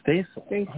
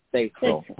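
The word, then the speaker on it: Stacil: Yeah, but it's like Stacy. It's S-T-A-C-I-L. – Oh, Stacel. Oh, nice. – Okay. Stacel. – Like Stacey Keach, but Stacel. Very good. All right. – It's like, I would say it rhymes like basil, pencil, but as in Stacel.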